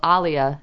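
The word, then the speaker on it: Alia: My mother got it from, well, Aaliyah is how it's actually pronounced, but only like a handful of people actually call me that.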